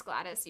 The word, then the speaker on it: Gladys: That's how you say it. It's Gladys, usually.